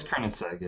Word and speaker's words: Koenigsegg: Okay, so the Koenigsegg, I don't know, how would you say this? K-O-E. – It's Koenigsegg, yeah, you said it right.